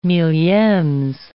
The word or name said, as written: milliemes